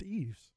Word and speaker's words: Yves: Y-V-E-S. Say that again. How are you and John saying this? – He pronounces it Yves. – Very interesting. – Which most of the time that spelling is pronounced Eves.